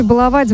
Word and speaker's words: баловать: Ударение в слове «баловать» в будущем изменится и будет падать только на первый слог, сообщил научный сотрудник Института русского языка имени Виноградова Владимир Пахомов.